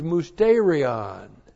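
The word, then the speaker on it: musterion (μυστήριον): And the church age is musterion. M-U-S-T, long E-R-I-O-N.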